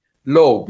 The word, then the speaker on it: Loeb: Is Loeb the right pronunciation? – Loeb, yes. Originally, Loeb. It's German, but in the US, they call me Loeb. I was born in Israel, and they call me Leib, actually.